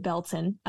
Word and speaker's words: Baileyton: If you're from the area, you pronounce it Belton, but it is spelled Baileyton.